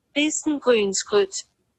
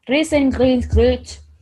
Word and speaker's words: Riesengrynsgröt: No, it is pronounced with the S. Okay, ready? – Really? Good yeah. – job. – Ready? – Riesengrynsgröt. – Riesengrynsgröt. – Okay, so the Y is more like an I.